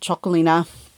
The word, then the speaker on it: Txakolina: It is spelled T-X-A-K-O-L-I-N-A. But you pronounce it Chocolina or Chocoli if it just has the I at the end.